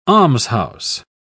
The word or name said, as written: almshouse